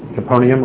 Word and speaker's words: Capernaum: The reason I say Capernaum, because look at how it's spelled, the last four letters, N-A-U-M. But you can pronounce it Capernaum or Capernaum.